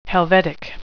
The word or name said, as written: helvetic